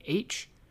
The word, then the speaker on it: Aytch: Company H, if you are not aware, it is spelled A-Y-T-C-H, is one of the better known memoirs of the Civil War.